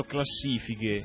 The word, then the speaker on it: classifiche: Pensare che dovevamo fare incetta di classifiche da mezzo mondo. – Guarda, ho delle... c'ho in serbo qualcosa. – Certo. Nota come ho messo bene l'accento, ho detto classifiche.